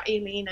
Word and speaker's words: Eileen: And I have to say, well, yes, it is spelled Eileen, but my mother's brought me up Eileen, and because of my, you know, and it's just very frustrating.